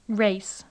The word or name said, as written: race